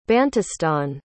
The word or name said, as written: bantustan